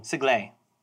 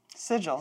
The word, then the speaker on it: Sigil: So, okay, S-I-G-I-L, the city, I know. – Is pronounced... – Sigil. – Sigle. – Sigil. – Celerice. – Consigliere. – We're split half 50-50 in the WotC office. – It's Sigil.